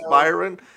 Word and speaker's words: Bryon: Yeah, unless it's one of those weird Antoine Jameson gimmicks where it's spelled all weird, but it's still the like maybe it's spelled Brian, but it's pronounced Byron. It's B-R-Y-O-N. I'm not B-R-Y-O-N.